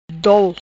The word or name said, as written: Dol